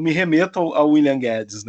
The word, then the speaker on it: Gaddis: eu tenho uma raiva do Paulo Guedes pelo seguinte, porque um dos meus autores favoritos é o norte-americano William Guedes, né, G-A-D-D-I-S, né, que se pronuncia como uhum. Guedes,